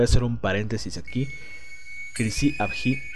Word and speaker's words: Chrysi Avgi: Al principio del podcast lo pronuncié mal, pero según tengo entendido, así se pronuncia, Crisi-Abji.